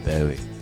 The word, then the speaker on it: Bowie: Even that letter that actually from his manager that said that his name would actually change to David Bowie. Bowie. – Yeah, and that's the official pronunciation because it's named after the Bowie knife.